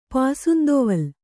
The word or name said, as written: ಪಾಸುಂದೊವಲ್